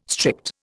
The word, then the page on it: strict: Indian